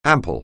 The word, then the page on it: ample: anglais